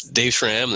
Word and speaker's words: Dave Schramm: That's Dave Schramm.